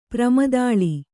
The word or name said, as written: ಪ್ರಮದಾಳಿ